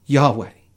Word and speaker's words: Yahweh: It was the four letters that possibly were pronounced Yahweh.